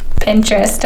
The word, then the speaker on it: Pinterest: Like I could spend a whole day looking at Pinterest.